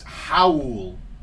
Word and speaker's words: Raul: His name is spelled Raul, but because he is of Portuguese or Brazilian descent, it's pronounced Howl.